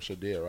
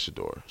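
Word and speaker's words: Shedeur: But even the quarterback, not about to say quarterback, how you say his name, Shadir, right? – I think it's Shador.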